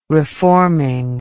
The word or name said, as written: reforming